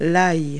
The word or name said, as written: garlic